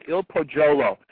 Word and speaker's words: Il Pajolo: So I know the community will welcome the advent of Il Pajolo. – Yeah, actually it's pronounced Il Pajolo, which means the balcony in Italian.